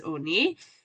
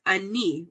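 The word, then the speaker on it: Oni (עני): Okay, we just know that the Nikud is Oni, the way it would be pronounced, but it doesn't have a Vav. And without that Vav there, you can read it as Ani, which is a poor person.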